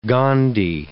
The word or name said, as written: Gondi